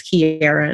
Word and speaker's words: Kierra: Of course, my name is Kiera and it's spelled K-I-E-R-R-A.